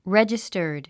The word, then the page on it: registered: rédʒistə r d; レジスタード